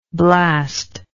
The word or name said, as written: blasty